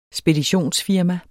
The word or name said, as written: speditionsfirma